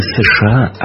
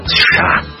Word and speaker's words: США: Он произносит не США, а именно США.